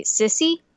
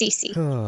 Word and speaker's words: Cece: Also, that's one of my main complaints about the dub, is that they call it Cece Sissy. – What? – Yeah, they call Cece Sissy, and I'm like, no, that's not how that name is pronounced, but that's okay. It's Cece.